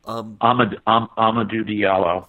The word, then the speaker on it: Amadou Diallo: Amadou Diallo.